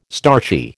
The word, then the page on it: starchy: American English